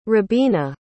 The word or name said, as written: robena